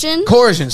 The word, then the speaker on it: coercion: September 9th, you tweeted, Corson, I forgot how I pronounce it. Fuck. – Coercion, sorry.